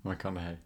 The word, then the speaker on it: McConaughey: McConaughey. ¿McConaughey? Sí. McConaughey.